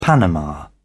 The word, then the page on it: panama: British English